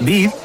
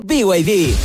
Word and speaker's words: BYD: ¿VIB? ¿No es B&B? – Nada que ver. Se escribe B&B, pero se dice BYD.